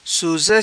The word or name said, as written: Suza